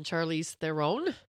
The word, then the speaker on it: Charlize Theron: Well, it stars Seth Rogen and Charlize Theron.